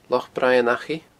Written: Loch Braigh an Achaidh

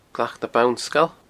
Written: Glac na Bannsgail

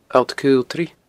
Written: alltcaoltraidh

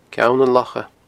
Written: ceannanlocha

